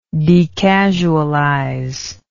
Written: decasualize

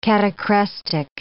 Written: catachrestic

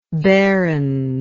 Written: behrens

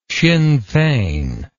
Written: Sinn Fein

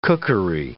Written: cookery

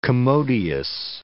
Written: commodious

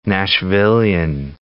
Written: Nashvillian

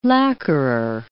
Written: lacquerer

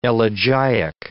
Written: elegiac

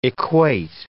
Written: equate

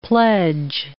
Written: pledge

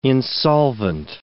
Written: insolvent

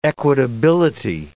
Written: equitability

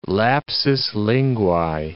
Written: lapsus linguae

